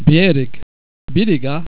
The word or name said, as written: bereg